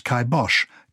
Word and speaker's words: kibosh: In Gaelic language, it was a caipin bash, shortened as kibash, kai meaning cap, and bash meaning death, but pronounced kibosh, kai rhyming with I, and bosh rhyming with the German name Bosch.